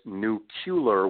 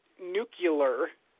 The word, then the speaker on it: nuclear: And the mispronunciation is nuclear, which is called a metathesis, where things get mixed around, switched up in the middle of a word.